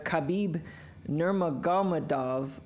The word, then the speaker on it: Khabib Nurmagomedov: So a few years ago, a UFC fighter, Khabib Nurmagomedov, said that... – without doubt. – I think that's how you say it. – Definitely how you said it.